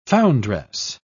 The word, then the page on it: foundress: British English